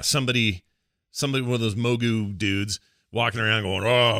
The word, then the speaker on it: Mogu: Mogu.